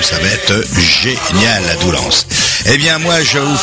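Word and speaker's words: Doullens: Mais pour le moment, je tiens à vous rappeler que là, le dimanche 13 septembre, je serai avec tous mes musiciens à Doulan, faut prononcer, mais il y a un S à la fin quand même.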